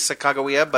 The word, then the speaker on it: Sacagawea: I think it could go either way. I think the generally accepted is Sacagawea, but.